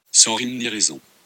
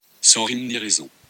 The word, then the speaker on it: sans rime ni raison: Now, please, you need to listen to how French people pronounce it. When you see this spelled out, do you think this is what it's supposed to sound like? – Sans rime ni raison.